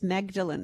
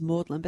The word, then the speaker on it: Magdalen: What about that stupid college that was – its name says Magdalen, but apparently it's pronounced Magdalen.